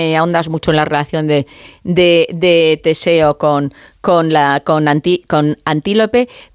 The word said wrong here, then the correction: Antíope: Pero lo he dicho mal el nombre, es Antíope.